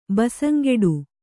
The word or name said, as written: ಬಸಂಗೆಡು